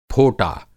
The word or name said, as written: फोटा